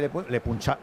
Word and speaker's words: Le Puncheur: Le Punxar. ¿Cómo se escribe Le Puncheur. – Le Puncheur. – Eso es.